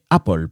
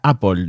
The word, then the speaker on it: Apple: Son los fans religiosos de Apple, porque sí, amigos, se pronuncia Apple, no Apple ni Apple.